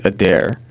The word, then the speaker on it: Adair: It's Adair. – It is Adair. – The way that you spell it is A-D-A-I-R.